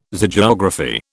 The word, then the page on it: Zoogeography: British English